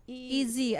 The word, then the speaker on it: Ez Mil: How do you pronounce it? – Easy. As in E tsaka Z. – Ah, E. Mill, M-I-L.